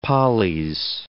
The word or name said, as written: polys